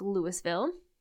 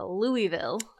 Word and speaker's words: Louisville: But there is a Louisville, Kentucky. – There's a Louisville. – Oh. Louis. So, slight difference. Okay. Same spelling, different pronunciation.